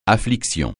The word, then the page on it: affliction: French